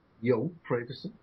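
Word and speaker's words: Joh Fredersen: So, Yo, is that how you pronounce it, right? Yo Frederson? – Yo Frederson.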